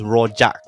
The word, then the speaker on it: Rojak: Is it Rojak? Royak? Rohak? – Okay, here, phonetics are very simple. You read what's written. There's no hidden sound. Okay. So it's Rojak, right? – So Rojak.